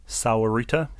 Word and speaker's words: Sahuarita: And this comes from, I'm pronouncing this wrong, Sahuarita, S-A-H-U-A-R-I-T-A, Sahuarita? – Sahuarita.